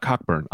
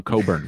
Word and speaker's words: Cockburn: There was clearly a college kid who went, no, I'm not Cockburn. I'm Coburn. We don't pronounce the CK.